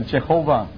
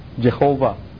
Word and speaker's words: Jehovah: 这跟听见 Bible 的 Jehovah 不太一样啊，几乎是一样啊，因为很多犹太人都到德国，他的 W 就读音的时候就变成 V 是吧？Jehovah。但是我们现在已经知道这个耶和华它是 Jehovah 这个字呢，很多英文版本都用大写的原因就是他们现在就按照犹太人的这种很敬虔的态度来读这个耶和华的名字。